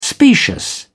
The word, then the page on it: specious: British English